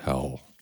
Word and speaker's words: HAL: Da kommuniziert der Astronaut, ich glaube die ganze zweite Filmhälfte, mit seinem äh, Zentralcomputer im Raumschiff und der heißt Hell. H-A-L, aber natürlich bewusst doppeldeutig als Hell, Hölle ausgesprochen.